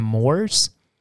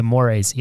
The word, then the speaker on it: mores: but in the mores. – Yeah. – Sorry, I said that wrong. I said that I knew how to say that word and I said it incorrectly. The mores. Yes.